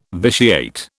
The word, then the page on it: vitiate: British English